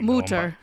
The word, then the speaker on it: Mater: Am I saying that right? There you go. Mater. You guys like Mutter. – Mutter.